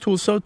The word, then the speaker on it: Tulsa: Parce qu'on veut parler de ce qui s'appelle le massacre de Tulsa. C'est comme ça qu'on le prononce, Tulsa, Oklahoma.